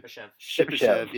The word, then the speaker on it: Shipachyov: I can't pronounce his last name. – Shipachyov, Yeah. yeah, that's a tough one.